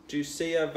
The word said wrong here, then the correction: Duca: Valentinoa. – Duca Valentinoa. – Duca. Oh, did I say Lucia? – Duca. – Duca. Yeah, whatever. Fastnet, Tempest, Twirls.